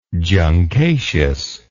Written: juncaceous

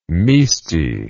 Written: misti

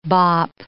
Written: bop

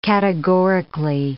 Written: categorically

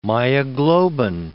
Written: myoglobin

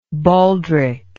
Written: baldricked

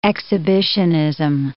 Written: exhibitionism

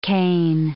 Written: Cain

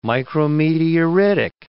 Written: micrometeoritic

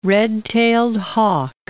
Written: red-tailed hawk

